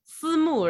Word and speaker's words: Simu: Because like Simu had like, or Simu, I guess if you want to say it properly, Simu, right?